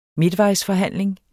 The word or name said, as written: midtvejsforhandling